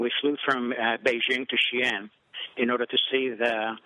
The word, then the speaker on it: Xi'an: Xi'an. – Xi'an. – Xi'an.